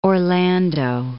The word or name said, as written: Orlando